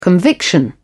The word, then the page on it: conviction: British English